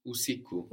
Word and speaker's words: usiku: Usaku. – Usiku. – Usiku. – Good.